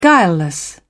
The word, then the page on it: guileless: British English